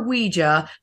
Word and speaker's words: Ouija: I prefer Ouija.